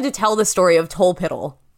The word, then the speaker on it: Tolpuddle: I think it's Tolpiddle, even though it's spelled okay. Tolpuddle.